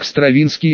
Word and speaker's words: Стравинский: Затем, Стравинский и Кандинский, простите, это произносится как Стравинский и Кандинский.